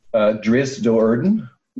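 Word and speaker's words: Drizzt Do'Urden: How do you pronounce the dark elf hero of Forgotten Realms' name? – Driss Doerden. – Okay. – That's how I say it. But I've had a lot of audiobooks read to me was it Scott Bovine or whatever? He's great. He's fantastic. He's the guy who does all the Salvatore audiobooks. And he pronounces it that way. So now I do.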